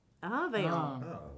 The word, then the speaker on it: Avignon: Avignon. – Avignon.